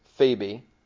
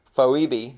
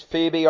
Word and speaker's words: Phoebe: and it's Phoebe. When I was younger, I used to try to say it Phoebe. but it's Phoebe, our sister, who is a servant.